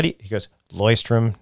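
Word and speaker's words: Leuchtturm: By the way, I learned that it's pronounced Loystrom.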